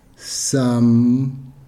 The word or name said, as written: sam